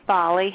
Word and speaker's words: Folly: Hey, my name's Folly. How are y'all? – Great. You're going to have to spell that name for me. Folly? – F-O-L-L-Y, just like it Folly.